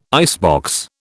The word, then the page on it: icebox: British English